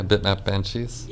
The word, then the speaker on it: Bitmap Banshees: Yeah, Bitmap Banshees. – Yeah. Was that like a side project? Or was that like, because you said like you interconnect everything, which is quite interesting. – Yeah, so Bitmap Banshees was a project, the first project that we did in the department.